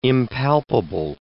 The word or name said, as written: impalpable